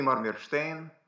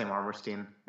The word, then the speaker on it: Marmerstein: Yeah, there's a few pronunciations. It's already the Americanized pronunciation of it anyway, so it's uh, at that point I'm just like, eh. It's it's originally Marmirstein, and then yeah, I say Marmirstein.